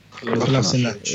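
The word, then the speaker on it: Kolašinac: Kolasinac.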